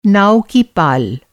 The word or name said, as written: पाल